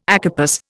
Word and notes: acarpous: Indian

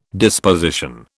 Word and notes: disposition: British English

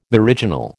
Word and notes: meridional: American English

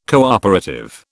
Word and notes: cooperative: British English

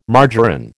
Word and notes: margarine: American English

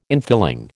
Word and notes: infilling: American English

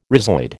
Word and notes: rhizoid: American English

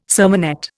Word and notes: sermonette: Indian

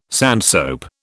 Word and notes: sandsoap: British English

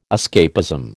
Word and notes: escapism: American English

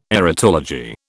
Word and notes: erotology: British English

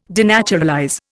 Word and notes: denaturalize: Indian

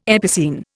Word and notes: epicene: Indian

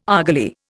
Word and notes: argali: Indian

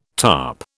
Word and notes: tarp: British English

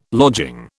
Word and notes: lodging: British English

